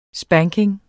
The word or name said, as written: spanking